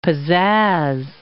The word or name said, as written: pizzazz